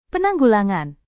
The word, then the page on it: penanggulangan: Indonesian